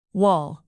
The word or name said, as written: wall